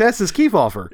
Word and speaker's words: Estes Kefauver: It's Estes Kefauver.